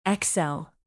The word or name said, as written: excel